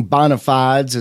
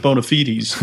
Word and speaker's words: bona fides: We pronounce it bona fides in the use that I'm used to.